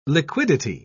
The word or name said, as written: liquidity